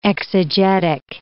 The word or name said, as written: exegetic